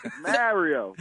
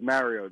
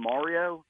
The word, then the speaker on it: Mario: this is Mario. They'd be like, oh, you mean Mario? No, I haven't been mispronouncing my name my entire life.